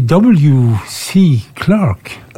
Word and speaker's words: W.C. Clark: Uttaler de W.C. Clark? – Ja. – Ja, som W.